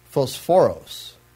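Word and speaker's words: phosphoros (φωσφόρος): And so the words Peter used here are phos, pharaoh, and when they're put together, they are pronounced phosphoros,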